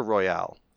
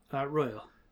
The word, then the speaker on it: Royal: Is it pronounced Royal or Royale? – Royal.